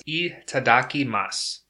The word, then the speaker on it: itadakimasu: Once again, that's itadaki masu. It's spelled itadakimasu. Itadaki masu.